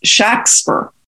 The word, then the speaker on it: Shakespeare: And that's the way he's pronounced his name, by the way. It was Shakespeare. If you look at the surviving records about him, they were spelling his name phonetically. So obviously he had to say his name to them, and they wrote it. And that's the majority of cases, his name is spelled that way.